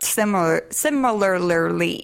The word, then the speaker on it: similarly: look alike, and you're built similarly. Is that, how do you say that word? – There's an extra syllable in there. – Similarly.